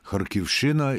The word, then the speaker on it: Харківщина: Дві є області, в яких місцеві люди ставлять наголос на «щі» – Харківщина і Сумщина.